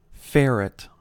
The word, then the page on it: ferret: American English